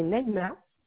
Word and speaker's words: Naïma: Alors, mon nom de famille, c'est Marais, M-A-R-A-I-S. – Oui. – Mon prénom, c'est Naïma. – Ça s'écrit comme ça se prononce? – Oui, oui, N-A-I-M-A.